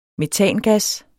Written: metangas

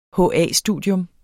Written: HA-studium

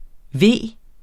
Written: VEERNE

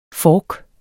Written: FORKE

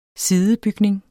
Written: SIDEBYGNINGS

